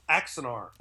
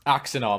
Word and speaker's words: Axanar: It's Axanar. – Axanar. Am I saying it wrong? Yeah, I apologize. Axanar. – So I know we share a common language. – Yeah. – And it separates us sometimes, but that's how you pronounce it in any part of the galaxy.